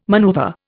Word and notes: maneuver: Indian

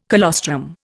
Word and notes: colostrum: Indian

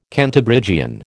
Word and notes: Cantabrigian: American English

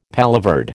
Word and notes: paloverde: American English

